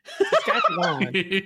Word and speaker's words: Saskatchewan: And it's Saskatchewan, not Saskatchewan. That is what I know. And the Canadians will be very pleased that I pronounced it correctly.